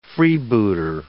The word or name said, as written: freebooter